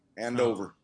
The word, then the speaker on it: Andover: No, that's right. – Andover. – Well, don't pronounce the R, but.